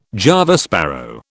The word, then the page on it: Java sparrow: British English